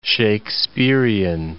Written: Shakespearian

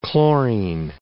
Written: chlorine

